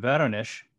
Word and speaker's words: Voronezh: Voronezh.